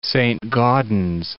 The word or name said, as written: Saintsbury